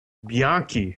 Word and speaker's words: Bianchi: Bianchi.